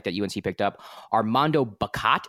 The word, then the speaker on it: Bacot: I think Bacot. – Bacot. – Armando like bacon, but Bacot. Oh, Bacot, as in like put this in the oven and bake it.